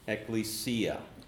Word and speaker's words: ekklesia: Now the Greek word, I've written it there for you, is ekklesia.